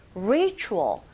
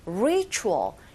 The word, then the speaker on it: ritual: Ritual, ritual. 이 T 사운드가 Ch 이런 소리가 들리게 되죠. 이때 Ch는 Z로 들리는 게 아니라 Ritual, 강세가 앞에 있기 때문에 Ritual begins.